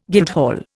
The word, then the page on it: guildhall: Indian